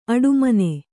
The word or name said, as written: ಆಡುಮನೆ